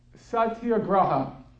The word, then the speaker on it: Satyagraha: Satyagraha, but maybe you pronounce it better than, are you Indian? Yeah, but okay, so say because you have the better pronunciation. Satyagraha. I think it was Satyagraha, but Satyagraha.